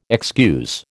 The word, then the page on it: excuse: American English